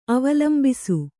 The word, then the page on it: ಅವಲಂಬಿಸು: avalambisu